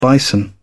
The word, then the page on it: bison: British English